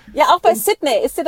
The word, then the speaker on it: Sydney: Sydney. Also Sydney. Mit dem I. Ja. Nicht so ein Singsang, sondern es ist ein I. Sydney. – Ja, mehr ein I. Mehr die Betonung auf einem längeren I.